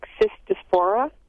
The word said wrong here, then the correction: Cytospora: Cytospora. – Cytospora, yeah.